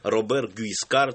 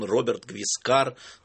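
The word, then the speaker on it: Robert Guiscard: Эта программа «Все так», и наш сегодняшний герой, о котором вы наверняка не слышали, и даже он и произносится, и читается по-разному в русском языке. То ли он Робер Гвискар, то ли он Роберт Гвискар, то ли он вообще непонятно кто.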